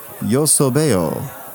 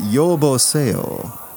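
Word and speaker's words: yeoboseyo: And may I say to you, yo sobeo. – Yo sobeo. – Yo sobeo. – Yo sobeo. – Oh, I'm sorry. I mispronounced that. Yo boseo. It's not yo soebo. It's yo.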